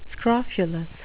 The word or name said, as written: scrofulous